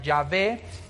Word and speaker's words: Yahvé: Entonces, esas mismas vocales puestas con estas consonantes, en algunos idiomas se pronuncia Jehová y en otros idiomas se pronuncia Yahvé, pero es exactamente el mismo Dios.